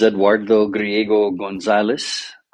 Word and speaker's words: Eduardo Griego Gonzalez: My name is Eduardo Griego Gonzalez.